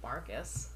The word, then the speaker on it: Marquis: And so they spell it Marquis, but it's said Marcus.